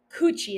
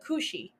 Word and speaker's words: Kuchi: I feel it's pronounced, it's not pronounced like coochie. It's pronounced like cushy. Cushy? Sushi cushy. But not coochie, you know? Like not vagina.